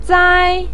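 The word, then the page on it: zai: tone 1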